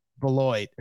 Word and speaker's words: Beloit: Illinois' favorite dispensary from city to suburbs, Wrigleyville to River North, Champaign to South Beloit.